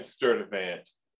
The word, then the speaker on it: Sturdivant: Lynn Sturtevant.